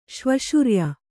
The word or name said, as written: ಶ್ವಶುರ್ಯ